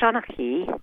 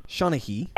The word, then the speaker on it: Sonahi: And, you know, tell us, because I introduced you as a Sonahi, right? Am I pronouncing it somewhat correctly? – It's pronounced Sonahi. – Sonahi.